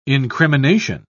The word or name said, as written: incrimination